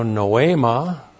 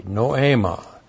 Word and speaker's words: noema: The now noema N-O long E-M-A noema.